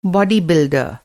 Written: बॉडी बिल्डर

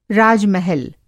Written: राज-महल

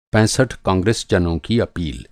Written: पैंसठ

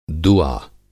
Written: दूआ

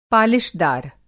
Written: पालिशदार

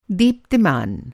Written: दीप्तिमान

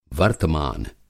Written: वर्तमान

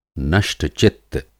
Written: नष्ट-चित्त

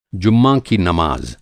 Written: जुम्मा

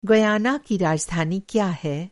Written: गयाना

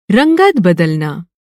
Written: रंगत

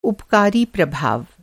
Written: उपकारी

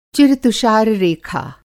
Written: चिर-तुषार-रेखा

